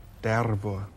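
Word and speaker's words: Dervo (дерево): You're going to have to roll your R's again, Fred. – Okay. – This is Dervo.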